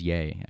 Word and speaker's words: Ye: I think it's Ye, actually. – Is it Ye? – Yeah. – It's spelled Y-E, so it's... – Showing your age there. – Oh, yeah, it's Kanye, so it would be Ye.